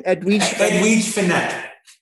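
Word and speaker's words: Edwige Fenech: How do you pronounce her name? – Edwige Finette.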